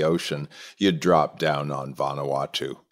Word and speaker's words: Vanuatu: Is it Vanuatu? Is that how you pronounce it? – Vanuatu is the name of the country.